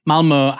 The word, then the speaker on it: Malmö: Now, Malmö has been, and by the way, that is how you pronounce it, has been the site of several large-scale pro-Palestine protests in the run-up to Saturday's Eurovision.